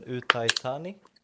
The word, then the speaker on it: Uthai Thani: Nu får du hjälpa mig lite kanske med uttalet här men visst heter klubben Utaitani? Yes. yes.